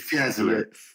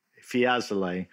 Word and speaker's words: Fiesole: Fiesole. – Fiesole.